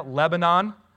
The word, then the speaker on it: Lebanon: It's modern day Lebanon, which is how you pronounce that word if you don't live in central Pennsylvania. Lebanon.